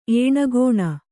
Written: ಏಣಗೋಣ

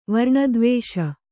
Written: ವರ್ಣದ್ವೇಷ

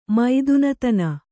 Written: ಮೈದುನತನ

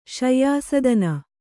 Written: ಶಯ್ಯಾಸದನ

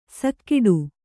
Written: ಸೈಗುಡು

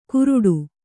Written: ಕುರುಡು

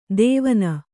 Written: ತೇವನ

